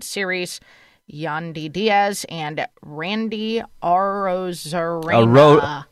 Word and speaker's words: Arozarena: Arozarena.